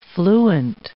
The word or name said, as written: fluent